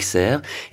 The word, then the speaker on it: Serf: Oui, on prononce pas normalement le F, mais bon, on dit Monique Serf, effectivement.